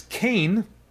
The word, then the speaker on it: Cayne: Next up is Kane, and that's spelled C-A-Y-N-E.